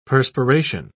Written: perspiration

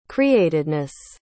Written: createdness

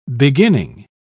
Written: beginning

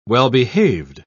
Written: well-behaved

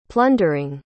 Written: plundering

